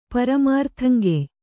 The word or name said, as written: ಪರಮಾರ್ಥಂಗೆಯ್